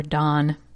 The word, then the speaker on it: Dan: From Dan, or Don, as I've been told it's pronounced.